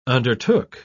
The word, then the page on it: undertook: British English